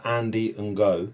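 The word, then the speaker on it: Andy Ngo: So this fella, Andy Ngoe, I think that's how you pronounce it, right? – Yeah. That's how I always read it.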